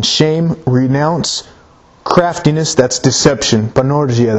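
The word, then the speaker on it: panorgia (πανουργία): In the original language, it's pronounced panorgia. That's how you'd say it.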